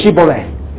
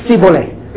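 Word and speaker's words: Shibboleth: And the Ephraimites, because of their dialect, they couldn't pronounce the sh sound. You see? So they can't say Shiboleth, they can say Siboleth.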